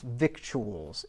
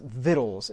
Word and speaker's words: victuals: Now, it's not pronounced victuals. It is pronounced victuals, as a matter of fact, and you can look that up in a dictionary.